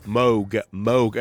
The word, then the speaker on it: Moog: And even now I kept having to remind myself, Moog.